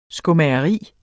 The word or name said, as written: skomageri